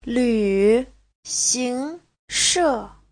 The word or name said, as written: lv3xing2she4